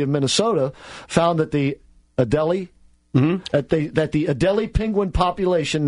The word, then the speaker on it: Adelie: Adelie is how you pronounce it.